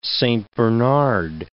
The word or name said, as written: Saint Bernard